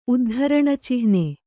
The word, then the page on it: ಉದ್ಧರಣಚಿಹ್ನೆ: uddharaṇacihne